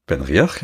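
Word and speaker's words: BenRiach: Dann habe ich hier Ben Riach. – Ben Riach, ja.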